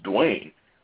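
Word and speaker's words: Dwyane: whose name is actually spelled Duyane, because for some reason the Y is in front of the A in his name, but it's still pronounced Dwayne.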